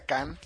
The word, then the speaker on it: Cannes: Por cierto, que sí, el abuelo me acaba de hacer la aclaración, se pronuncia Cannes, la ciudad de donde se hace el famoso festival francés, es el festival de Cannes, entonces pues ahora sí que vamos a tener cuidado con esas palabras.